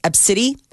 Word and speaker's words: ABCDE: which is spelled ABCDE. It's pronounced Ebsidi.